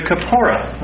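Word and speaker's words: kapporeth: Well, the mercy seat is called the kapporah, and it comes from this Hebrew word kafar.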